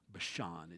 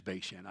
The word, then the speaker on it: Bashan: In the Hebrew, the emphasis is on the second half of the word Bashan. It's not Bashan. Unless, of course, you're redneck, then it's Bashan. I think that'd be fine. But it's Bashan, the oaks of Bashan.